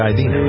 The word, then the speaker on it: Idina: It's Idina, right? – Yes, it is.